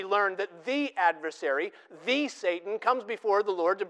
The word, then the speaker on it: Satan: The word there in the Hebrew is Satan. We give it a different pronunciation. We call it Satan.